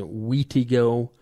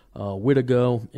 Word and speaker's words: Wendigo: And different spellings of the name, but yeah, pronounced Wendigo. Some in the past, Native Americans have pronounced it Wheatigo, Wittigo.